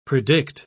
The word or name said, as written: predict